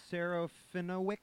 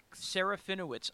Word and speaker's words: Serafinowicz: Peter Serafinowicz? – Serafinowicz, I believe.